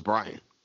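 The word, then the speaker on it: Bryon: First off, I've been saying his name wrong for years. I thought it was Byron Russell. I didn't know it was Brian.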